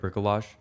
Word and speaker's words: Bricolage: I mean, that's how you say it, right? Bricolage? – Yeah.